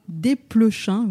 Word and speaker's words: Desplechin: Film français signé Arnaud Desplechin, oui je prononce ça euh, délibérément parce que c'est comme ça qu'on doit dire, avec une très belle distribution, Sarah Forestier, Léa Seydoux, Roche Dizem et Antoine Reynard.